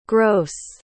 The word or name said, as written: grosse